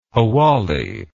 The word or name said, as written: hawalli